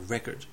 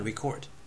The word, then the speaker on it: record: record 動詞で記録する、 record という単語はいわゆる名前動語、名詞の時に前にアクセント record、動詞の時には record というふうに後ろにアクセントがいきます。